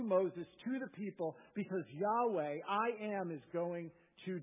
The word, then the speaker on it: Yahweh: And despite the popularity of this pronunciation at one time, it's largely understood to be a mispronunciation, and most scholars believe the most accurate way to pronounce it would be Yahweh.